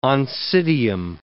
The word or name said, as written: oncidium